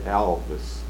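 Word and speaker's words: Alves: All right, let's talk a little bit about the Alvey Street School. I think I'm pronouncing that right. – Alvis. – Alvis, mm-hmm. Okay. That's A-L-V-E-S.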